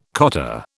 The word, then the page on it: cottar: British English